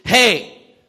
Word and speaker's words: He: Neat thing about the fifth letter of the Hebrew alphabet is you pronounce it, hey.